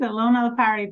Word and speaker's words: Elona Lopari: Did I say that right? – Yes, you did. Elona Lopari.